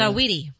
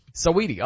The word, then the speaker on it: Saweetie: How do you pronounce it though? – Saweetie. – Saweetie. Okay, so I was right.